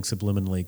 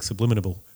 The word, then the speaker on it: subliminal: Anyway, George Bush, when he was asked about it, he can't pronounce subliminal. He kept saying subliminable.